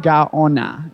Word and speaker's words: Gaona: Gaona. – Gaona. – Spelled G-A-O-N-A.